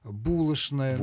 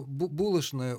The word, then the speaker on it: булочная: Ну, десяток, может быть, полтора слов, в которых требуется произношение «ш» на месте буквы «ч». Это «что» и его производные, «конечно». Это скучно. – Скучно. Ну, вот яичница, булочная. – Двоечник. – Ну, двоечник. Но булочная уже иногда произносит и так, и так.